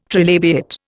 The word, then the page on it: trilabiate: Indian